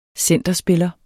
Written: centerspiller

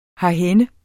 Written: herhenne

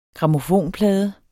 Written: grammofonplade

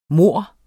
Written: morren

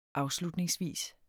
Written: afslutningsvis

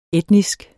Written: etnisk

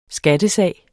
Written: skattesag

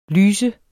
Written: lyste

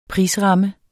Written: prisramme

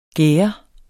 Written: gære

